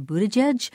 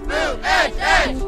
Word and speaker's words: Buttigieg: And if you don't yet know how to pronounce candidates' name, say Buttigieg, just go outside. – Buttigieg! Buttigieg!